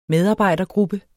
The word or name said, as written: medarbejdergruppe